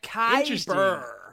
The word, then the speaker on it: Kaiburr: Is it pronounced Kyber? – Yeah, it's like K-A-I-B-U-R-R. It's like Kyber.